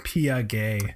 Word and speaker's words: Piguet: How do you say Piguet? How do you say it? – It's Pia Gay.